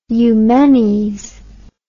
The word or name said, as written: eumenes